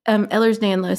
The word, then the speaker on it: Ehlers-Danlos: So I saw where you said on TikTok that you also have Ehlers-Danlos. Is that how you say it? – Yes, it's Ehlers-Danlos syndrome.